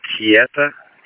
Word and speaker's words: Keita: I got a last name of Kieta, K-E-I-T-A. First name of, I'll spell it as T-H-I-E-R-N-O, middle Emma.